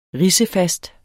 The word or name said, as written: ridsefast